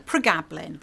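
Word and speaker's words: pregabalin: Is that pregablin? – Pregablin. – Say again. – Pregablin. – Pregablin, okay.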